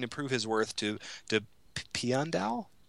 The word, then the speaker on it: Piandao: Pian Dao. – Pian Dao, just like it's spelled, duh.